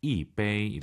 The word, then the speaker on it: 一杯: 一杯. – 오, 청취자분들 지금 이杯 일성으로 하시는 분 계시네요. 숫자 1 다음에 일성이 오면은 숫자 1이 사성으로 바뀝니다. 이杯 이렇게 발음하세요.